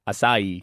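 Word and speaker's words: açaí: Number 1 on the list, and this one I constantly get wrong, asahi. Acai. – Oh, acai bowls. – Acai bowls. – Acai. – Acai. Yeah. All right, mate. Acai. You know how to say it.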